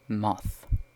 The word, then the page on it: moth: British English